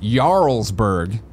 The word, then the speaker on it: Jarlsberg: It's pronounced Jarlsberg. Think Skyrim.